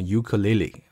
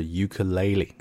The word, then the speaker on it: ukulele: 注意这个词的读音啊，大家不要想当然的读成 ukulele、啊、这个第一个那个 e 字母、啊、它发的是双元音 a、啊、所以正确的发音呢应该是 ukulele、 okay?